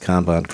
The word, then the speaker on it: Kanban: Just for the Agile Revolution listeners, I'm glad that somebody actually pronounced Kanban correctly.